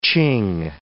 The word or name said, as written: Ch'ing